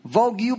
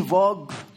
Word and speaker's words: Vogue: Babasahin ko lang, ma'am, Vogue po yun, hindi Vogue. Vogue ang tamang pagbigkas, Inday. O sige na nga, ma'am, Vogue na. There's no need to argue. Ayun ang sabi niya. No? Matalino, no? Vogue o Vogue?